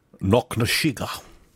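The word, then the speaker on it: Knocknasheega: You have to say it like Connery, though. – Knocknashiga.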